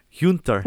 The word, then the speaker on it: Gunter: Okay, so in Flemish we just say Hunter Habets. Hunter.